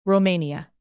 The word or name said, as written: romania